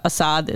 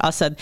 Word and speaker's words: Assad: Yeah, in Syria, Assad is how most Americans pronounce it. When you're Muslim, you pronounce it Assad.